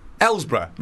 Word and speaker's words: Ellesborough: Have I pronounced that correctly? Ellesborough? – Oh, it's here. – Yeah. I don't know that off by heart, do I? – Ellesborough. – Ellsborough. – I'll say it's Ellsborough.